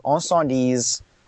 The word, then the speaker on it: Incendies: It's a French Canadian movie called Incendies, or Incendies if you don't speak French, because it's called spelled I N C E N. Well, it's called Incendies, but it's spelled I N C E N D I E S.